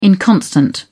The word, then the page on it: inconstant: British English